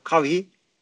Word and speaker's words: Kawhi: LeBron ve Kawhi nasıl okunuyor? kavi Bilmediğim yerden sordunuz. kavi nasıl olur ya. İlkinde doğru okudun abi.